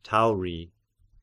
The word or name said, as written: Tauri